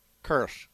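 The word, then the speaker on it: Karros: Correct pronunciation of his last name? – Kersh.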